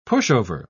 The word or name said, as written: pushover